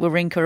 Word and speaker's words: Wawrinka: Yes, I am pronouncing it Wawrinka. Apparently, we've been pronouncing his name wrong for the past 15 years.